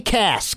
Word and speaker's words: Cass: Kelly Cass. Kelly C-A-S-S. It's like ass, but with a C.